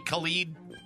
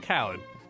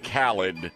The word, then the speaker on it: Khaled: Diddy, DJ Khaled, Angela. – Wasn't that DJ Khalid? – It's Khaled. – It's supposed to sound DJ more Middle Eastern.